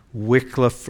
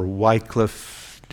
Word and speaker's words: Wycliffe: But they were John Wycliffe or Wycliffe, different pronunciations, in England, professor there, and John Huss.